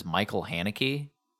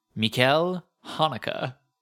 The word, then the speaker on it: Michael Haneke: This is a corrections department I refer to him as Michael Haneke. Uh-huh. This is not how you pronounce his name. – Haneke. – Mikkel Haneke. Pretty funny.